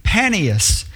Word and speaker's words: Banias: Now, in the language, the word P, or the sound P, isn't pronounced, so it's changed to B. So the way that we would pronounce the word Banias would be Panias, and it means the realm of Pan.